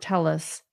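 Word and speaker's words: Telles: They are stating that Robert Tellis pronounced his name Tellis. And that is true, that he would pronounce it in person that way on his political commercials, campaign, and in the paper, and in the media.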